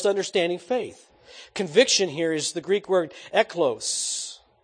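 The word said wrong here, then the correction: elenchos: Eglekos. I'll say it right yet. Elenkos.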